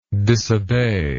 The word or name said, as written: disobeyer